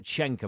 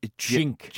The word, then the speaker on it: Cenk: So it's not Cenk, apparently. You're telling me it's... – It's Cenk. Cenk. Cenk. – Cenk. – Cenk, yeah, apparently.